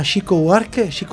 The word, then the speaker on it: Chico Buarque: Vamos a escuchar una canción de un señor que se llama Chico Buarque, así se pronuncia, que es O Que Será, que habla en verbos en portugués, pero tiene mucho que ver con los verbos.